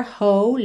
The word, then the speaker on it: whole: Make it simple to last your whole life long. Whole. Essa palavra inteira, inteiro, W-H-O-L-E, tem a pronúncia whole. Whole. Ou seja, na verdade você ignora esse W inicial, ok?